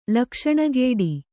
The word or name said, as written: ಲಕ್ಷಣಗೇಡಿ